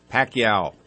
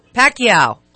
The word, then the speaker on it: Pacquiao: So all together now, how do we say it? – Pacquiao. – Pacquiao.